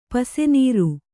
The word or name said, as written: ಪಿಸನಾರಿ